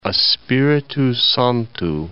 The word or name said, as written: Espirito Santo